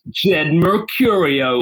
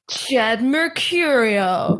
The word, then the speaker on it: Jed Mercurio: And if I could just take you up on a second point, you've been pronouncing the name Jed Mercurio as if it's a normal name, and I can't have that. So, like, you should be saying Jed Mercurio, or just say it like that maybe next time. – Jed Mercurio. Yeah, is that better? – Yeah, that's much better.